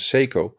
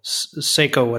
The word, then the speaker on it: Seiko: One last thing, because I got some comments about how I pronounce Seiko. It's really a tongue breaker for me because in the Netherlands we say Seiko.